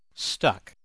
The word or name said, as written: stuck